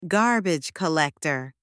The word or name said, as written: garbage collector